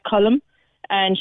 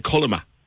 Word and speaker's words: Colma: I would have said Colma. – Yeah, Colma, C-O-L-M-A. And everyone said, oh, is it the female version of Colm?